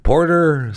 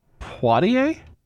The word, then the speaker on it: Poitier: Is it Portier? Porter? Sidney Portier? I want to make sure I pronounce it right. I don't want to. I know this. It's not Portier. And it's not Porter. It's Sidney. Poitier? Okay.